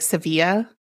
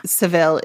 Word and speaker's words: Sevilla: I think it's technically pronounced like Sevilla, but like I think Seville is a appropriate or not an appropriate, just like accepted.